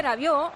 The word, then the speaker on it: Rabiot: Y Rabiot, no, se dice Rabiot. Ay, madre. – Rabiot. – Qué vergüenza. – O sea, no se pronuncia ni la T de Rabiot, ni la D de Giroud.